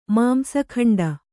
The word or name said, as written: ಮಾಂಸಖಂಡ